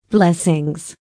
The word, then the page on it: blessings: American English